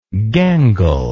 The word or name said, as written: gangle